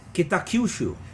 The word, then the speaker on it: Kitakyushu: Kitakyushu.